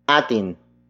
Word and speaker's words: atin: And that happens to be atin. Atin. Atin. Atin is spelled as A-T-I-N.